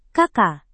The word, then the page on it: kk: indonesian